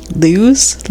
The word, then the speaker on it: Lewes: It's spelled L-E-W-E-S. Lewes?